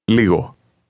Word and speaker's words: Λίγο: Did you hear the soft G sound in the middle of that? Listen and repeat again, trying to make your pronunciation sound exactly like the speaker's. – Λίγο. Λίγο. – It's somewhat like a cross between the G in the word go and the Y in yes.